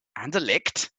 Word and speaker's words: Anderlecht: Anderlecht. – And we want to buy the licht. No? – Hey. – Okay. – He's not Belgian. – All right, okay. He's moved over to Anderlecht,